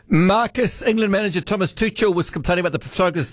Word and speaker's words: Tuchel: Tuchel, his surname is pronounced.